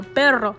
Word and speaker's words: perro: So, um, for example, for example, the word um, per- perro, which I still kind of am working on perro. It's spelled P E R R O. If my native Spanish speaker friends, they always joke with me about my double R and they're like, oh my gosh, gringa.